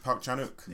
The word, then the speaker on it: Park Chan-wook: Is it Park Chan-wook? – It is. – Is that how it's pronounced? Okay.